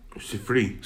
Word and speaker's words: Sifri: Okay, elaborating on the comment of Safira on the uh, Safari. How do you pronounce that? Sifri. Sifri. Thank you.